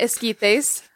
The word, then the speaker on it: esquites: Yeah, we went to Sergio's Delights and we got esquites. – Are you trying to say Sergio? – We went to Sergio's Delights. – I'm kidding. – Yeah. But yeah, Sergio. We went there, got the esquites, and I made sure to get myself a second cup for later.